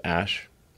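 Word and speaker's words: Æ: And then the AE is like pronounced Ash.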